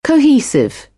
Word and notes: cohesive: British English; əʊ

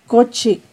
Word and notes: cochi: Náhuatl

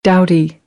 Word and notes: doughty: American English; ɾ - t

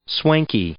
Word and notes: swanky: adjective

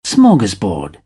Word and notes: smorgasbord: British English

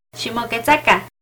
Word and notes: Ximoquetzacan: Náhuatl

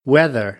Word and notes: weather: American English

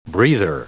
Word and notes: breather: noun

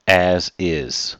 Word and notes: as is: idiom

